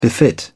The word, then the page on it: befit: British English